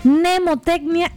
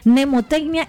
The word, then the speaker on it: mnemotecnia: Entonces, una de las técnicas que nosotros aplicamos, de hecho, usted que nos está escuchando y usted quiere investigarla, se llama mnemotecnia interactiva. Se escribe con M, ¿oyó? Mnemotecnia interactiva.